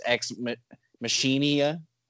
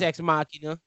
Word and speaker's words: Ex Machina: Like, but is it pronounced the same? Like, is this now just Ex Machinia? – No, I think it's Ex Machina.